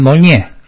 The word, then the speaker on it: Meaulnes: Ese libro, que en español es El Gran Meaulnes, El Gran Meaulnes se escribe en español, se pronuncia El Gran Monnier.